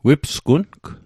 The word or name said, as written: wipsgung